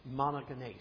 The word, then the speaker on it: monogenes: That original word is pronounced monogenes.